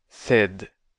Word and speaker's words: sed: 性の発音について、英語の red 赤のように言ってみてね。 said 天語 said 次。